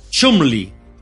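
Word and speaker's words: Cholmondeley: Cholmon de Ley, pero la pronunciación era Chumley.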